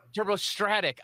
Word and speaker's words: turbostratic: We're going to talk about turbostatic graphene. Did I say that right, Kevin? – Yeah, turbostatic. Yep. – Turbostratic. I already messed it up.